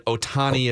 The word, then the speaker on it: Ohtani: Purposes of this right conversation, we know that Ohtani is how you pronounce the second part.